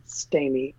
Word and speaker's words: Stamey: But it's spelled W-8-I-N-G, the number 4, G-U-F-F-M-A-N, Waiting for Guffman. And then my Instagram is at Christopher Ryan Stamey, S-T-A-M-E-Y.